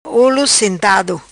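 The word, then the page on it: Olo Sentado: patua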